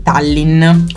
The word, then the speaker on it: Tallinn: di Tallinn. Corretta la pronuncia? – Corretta, mi sto sbagliando tutto il tempo.